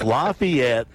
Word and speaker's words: Lafayette: It's Lafayette, Louisiana.